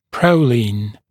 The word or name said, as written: proline